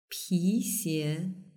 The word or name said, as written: pixie